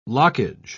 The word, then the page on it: lockage: British English